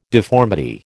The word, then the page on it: deformity: American English